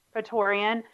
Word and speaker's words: Praetorian: So for Halloween, he's a Praetorian guard. I'm not saying that right. Do y'all know what I'm talking about? The red gold? – How do you say that? – Praetorian.